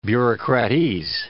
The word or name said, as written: bureaucratese